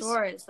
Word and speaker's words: Soares: Soares, that's how you pronounce it.